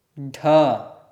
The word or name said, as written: ḍh